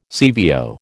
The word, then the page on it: CVO: American English